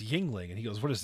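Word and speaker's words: Yuengling: I mean, we're talking about a man who pronounced Yingling Brewery Yang Long.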